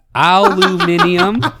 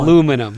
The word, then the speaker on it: aluminium: Aluminium. – Okay, or this is a real aluminum. – One. Yeah, aluminium is how the Brits say.